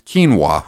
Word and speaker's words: quinoa: Then I realized it wasn't rice, which I used to pronounce phonetically and have since learned is pronounced quinoa.